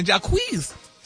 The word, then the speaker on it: Jacquees: Jacquees.